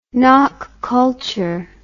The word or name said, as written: Nok culture